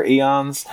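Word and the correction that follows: Aeros: Akron Arrows. – Arrows, okay. Yeah.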